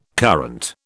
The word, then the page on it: current: British English